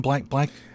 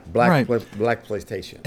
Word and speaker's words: Blaxploitation: Blaxploitation. – Yeah. Okay. – Yes, it's a combination of exploitation and black. Blaxploitation.